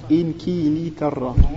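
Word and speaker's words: إنكلترا: Ouais, pour t'apprendre, pour tu sais que au moins ça s'écrit comme ça, se prononce comme ça. Inkilitarra. – Normalement, c'est écrit. – Ils sont là. – Tam. Ah, Inke. Inke. Inkilitarra. – Inkilitarra. – Inkilitarra. Inkilitarra. Donc c'est Inkilitarra.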